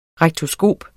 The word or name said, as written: rektoskop